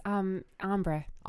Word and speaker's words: ombre: It's ombre. – Ombre. – Ombre. I always mispronounce it.